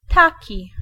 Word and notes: autant: France; /o.tɑ̃/

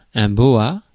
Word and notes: boa: France; [bo.a]

